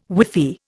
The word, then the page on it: whiffy: Indian